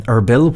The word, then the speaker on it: Erbil: And then for the next few days, the Pope will be going to Erbil, where you said that correctly that bombing was outside the airport, a rocket launcher just last week.